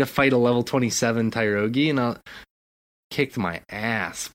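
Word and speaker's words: Tyrogue: and then all of a sudden this little Kyrogi, is that how you pronounce his name? Kyrogi? I'm pretty sure. – Yeah. – He just Tyrogi, the little fighting Pokemon.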